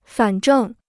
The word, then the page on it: 反正: fǎn zhèng